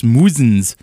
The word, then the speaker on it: Muzzins: Yes, and we started that block off with this Muzzins And the it sounds like cousins. – We gotta cousins. Keep the, we gotta keep the rhymes with cousins Muzzins, thing in our bio now because people are mispronouncing it left and right.